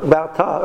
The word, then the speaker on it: Va'atah: Atah with an ayin. Right? If I be good, sorry, I'd pronounce it "Va'atah."